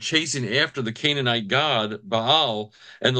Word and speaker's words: Baal: You know, in the word Baal, you know, Baal, you know, sometimes we say Baal, it's actually pronounced Baal, but the word Baal means to rule over or to marry or to possess or to own.